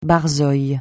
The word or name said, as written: barzoï